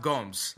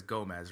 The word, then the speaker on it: Gomes: So yeah, it's pronounced Gomes, right? Like that's how it's pronounced. But you know, we try, like when I see it, I automatically says Gomez, right? Because that's just like the Spanish style.